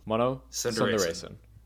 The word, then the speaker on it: Mano Sundaresan: Mano. Sundaresan.